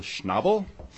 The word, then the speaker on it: Schnabel: My name is Bill Schnabel. Rhymes with bobble.